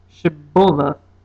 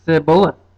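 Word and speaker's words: Shibboleth: If the man said, "No, I'm not," they would tell him to say, "Shiboleth." If he was from Ephraim, he would say, "Siboleth," because people from Ephraim could not pronounce the word correctly.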